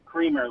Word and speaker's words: Creamer: But no, my last name is actually Creamer, like coffee creamer.